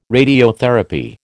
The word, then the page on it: radiotherapy: American English